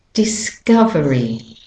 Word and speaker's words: discovery: Okay, the next word is discovery. Discovery. Can you hear that the cov is the main stressed syllable? So the op in this word is saying up. Okay, it's not discovery, it's discovery. Discovery. Okay, discovery.